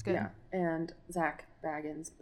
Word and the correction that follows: Bagans: I think it's Bagans. – Bagans, because then he'd be a hobbit otherwise. Baggins. yeah, he's not a hobbit. – Baggins he's not cool enough.